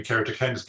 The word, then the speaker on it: keratoconus: Oh, keratoconus. – Oh. – Is there a direct link between am I saying it right? – Keratoconus.